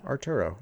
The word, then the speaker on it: Arturo: Today we have an email from Arturo. Is that how you'd say his name or her name? – His name, Arturo. It's Arthur in Spanish.